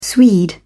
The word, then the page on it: swede: British English